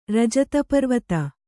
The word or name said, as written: ರಜತಪರ್ವತ